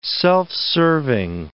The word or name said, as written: self-serving